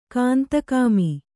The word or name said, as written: ಕಾಂತಕಾಮಿ